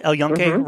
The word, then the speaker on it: El Yunque: So, and I remember just being, I did the El Yunque, I think, is that how you pronounce it? El Yunque Rainforest? – Exactly.